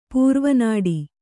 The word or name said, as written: ಪೂರ್ವನಾಡಿ